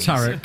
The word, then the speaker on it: tarot: Tarot, all right. Is it spelled? – There's a T on the end. – There's a T on the end, yeah, that's why. – Silent T. – Tarot card.